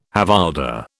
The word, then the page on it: havildar: British English